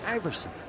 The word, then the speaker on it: Aeverson: Iverson, who spells his name, that's a unique spelling, is it is A-E-V-E-R-S-O-N, and it's pronounced Iverson.